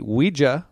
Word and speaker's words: Ouija: I'm going to say it correctly, too. The Ouija board. Not Ouija, like I've always said since I was a little kid.